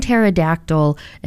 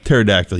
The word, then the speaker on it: pterodactyl: You can't say prestidigitation, but pterodactyl you get. – The P is silent? – Okay, whatever.